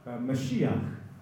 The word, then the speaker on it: Mashiach: Or actually three different languages, because Christ is English for the Greek Christos, which is the Greek translation of the Hebrew Mashiach. Now we pronounce it in English, Messiah, but it's in Hebrew it's Mashiach, in English Messiah, but in Greek it's Christos.